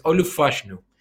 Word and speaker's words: Olu Fashanu: Ya he tenido que escuchar varias veces la pronunciación para decirlo bien, pero es Olu Fashanu.